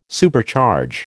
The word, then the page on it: Supercharge: American English